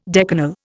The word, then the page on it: decanal: Indian